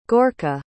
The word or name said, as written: gorka